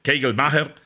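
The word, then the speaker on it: Kagelmacher: Kajelmacher o Kegelmacher, pues Kegelmacher porque tiene un apellido tirándole a alemán, Kegelmacher, es la forma correcta de pronunciarlo,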